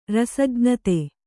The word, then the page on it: ರಸಜ್ಞತೆ: Kannada; rasajñate